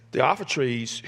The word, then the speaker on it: Diotrephes: There's two ways to pronounce that in the Greek biblical Greek or classical Greek. We'll go with biblical Greek. Diotrephes, who loves to be first among them, does not accept what they say.